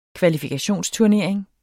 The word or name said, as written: KVALIFIKATIONSTURNERING